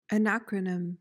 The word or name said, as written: anacronym